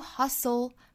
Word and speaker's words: hustle: 还要跟前面的 s 去组合 hustle 我们注意这个单词里是含有一个字母 t 的但是字母 t 是不发音的哈我们在发的时候是 s 和 o 连在一起发 hustle If you hustle to pursue 这个词重音在后 pursue what is meaningful to you now meaningful 的 meaning 第一个音节注意是紧音发的时候呢上下齿之间没有缝隙 meaningful What is meaningful to you now?